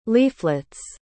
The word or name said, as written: leaflets